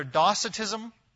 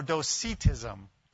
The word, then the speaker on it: Docetism: Now, again, I've heard this pronounced twice, two different ways, so I will say it first once and the other, and then I probably will mix it up as I go. But either Docetism or Docetism. Docetism or Docetism. And for those who are interested in spelling, it's D-O-C-E-T-I-S-M.